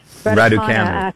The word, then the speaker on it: Raducanu: Raducanu.